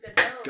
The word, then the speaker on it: Gadot: Gal Gadot. – Neither is like... – Wonder Woman. – It's an odd name. – The T is silent. – I'm sorry, Gal Gadot? – Gadot.